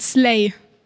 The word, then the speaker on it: Slay: Wie spricht man das richtig aus? Slay? – Ja, man spricht Slay aus.